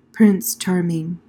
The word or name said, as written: prince charming